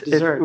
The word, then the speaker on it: Dessert: Hey everybody, this is Sam Taggart with Graham Desert or Dessert. – Dessert. – We're switching it to Dessert now. – Yeah, we literally just switched my name. – We did. I mean, it's spelled like dessert, so it's like, let's own that.